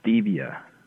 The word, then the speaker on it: stevia: How do you say it? – Stevia. S-T-E-V-I-A. Stevia. – Oh, like Steve.